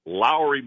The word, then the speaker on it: Lauri: The Cavs PR folks, when we acquired Markkanen, said it's just like Kyle Lowry of then Toronto, now Miami. So it's Lauri Markkanen and Howell Neto.